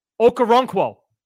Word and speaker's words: Okoronkwo: Oh, it's Obanaya Okoronkwo. See, sure? I know how to say his name. You don't. – Yeah. Oh, you know what? I listened to you butcher it the first podcast we did, too. Okoronkwo. You probably had to say it more than I have. – Okoronkwo. – Double O. Double O.